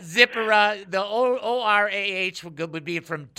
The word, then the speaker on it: Zipporah: Well, I guess I've been pronouncing it wrong all these years. I've got J. Vernon McGee's edition, and he's got accents on a different syllable. – Okay. – He's got Zipporah.